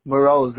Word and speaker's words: Mroz: Danny Moroz. Last name is spelled M-R-O-Z.